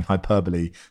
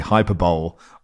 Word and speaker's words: hyperbole: Just a note that Mark Langdon did indeed, instead of saying hyperbole, say hyperbole. I let it slide. I don't know why. – But the reason I let it slide is because I used to pronounce it hyperbole as well until someone corrected me.